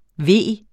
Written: vigende

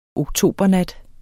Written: oktobernat

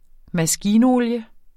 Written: MASKINOLIE

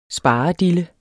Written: sparedille

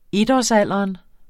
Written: etårsalderen

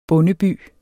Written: bondeby

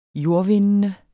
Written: jordvindende

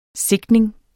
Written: sigtning

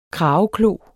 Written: krageklo

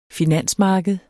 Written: finansmarked

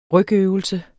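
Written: rygøvelse

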